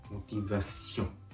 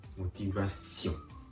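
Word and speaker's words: motivation: この N の発音を口で「ん?」と発音しないところがポイントモティバッションモティバッションですからモティバッションまでで発音終わり「ん?」は気持ち鼻から本当に息を抜かすように「ん?」と発音してあげるこうすると美ボーイがうまく発音できます。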